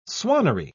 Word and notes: swannery: British English

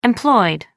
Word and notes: employed: American English

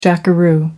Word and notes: jackaroo: American English

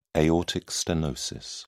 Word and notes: aortic stenosis: British English

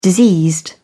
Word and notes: diseased: British English

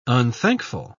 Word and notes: unthankful: British English

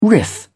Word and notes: riff: British English

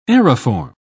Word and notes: aeriform: British English

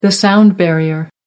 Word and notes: sound barrier: American English

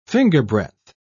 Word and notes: fingerbreadth: British English

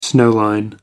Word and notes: snow line: American English

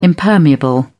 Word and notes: impermeable: British English

